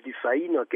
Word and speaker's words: Dufay: A kiejtésről pedig annyit, hogy ugye sokan Franciaországban is úgy ejtik ki a nevét, hogy Dufay. Ami hát lehet, hogy a mai kiejtési szabályok szerint helyes, de valójában ez Dufay-nak ejtendő, ugyanis ő ugye Pikardiában született, Észak-Franciaországban,